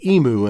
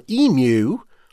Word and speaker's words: emu: He told me it would be unthinkable to say emu in Australia. But, he said, it's understandable that Americans may use the traditional emu or the Americanized emu,